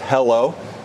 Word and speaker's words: HELO: The ticker, wait for it, is H-E-L-O, pronounced hello.